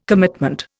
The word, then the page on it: commitment: Indian